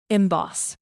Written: emboss

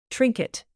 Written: trinket

